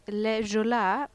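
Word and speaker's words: Le Joola: Namnet Le Jolin, jag uttalar det på franska för att eh, i Senegal pratar man franska.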